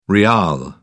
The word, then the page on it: rial: British English